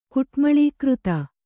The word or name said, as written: ಕುಟ್ಮಳೀಕೃತ